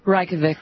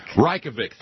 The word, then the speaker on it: Reykjavik: In 1986 at the Kate, give me, how do you say it again? In my ear. – Reykjavik. – Reykjavik, thank you.